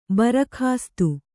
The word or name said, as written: ಭರಕಾಸ್ತು